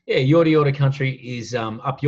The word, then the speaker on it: Yorta Yorta: Yep, Yorta Yorta, spelled Y-O-R-T-A, and you say it twice.